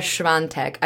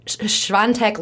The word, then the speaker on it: Świątek: We have Iga Świątek. I don't know where the N is in that name, but apparently that's how you pronounce it.